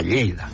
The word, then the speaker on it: Lleida: Y Tarragona no lo cambian, pero en cambio Lérida es... Dicen Lleida. O sea, Lleida. – Molt bé, Lleida, eh?